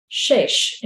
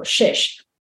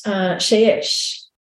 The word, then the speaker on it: shesh: Well, not so coincidentally, the same word for linen garments is the one for alabaster, shesh in Hebrew, shesh. And it's shin shin, Hebrew letter shin with Hebrew letter shin. So it's spelled the same forward and backward. Sometimes you'll see it as sheish or shesh with a yod in between.